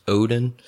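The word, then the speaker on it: Odon: I believe it's pronounced Odin.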